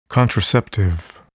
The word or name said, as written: contraceptive